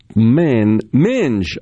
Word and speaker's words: Minge: Minge, okay, pronunciation is like hinge. That's Cedric from Dedo. All right, so Minge.